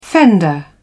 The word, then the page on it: fender: British English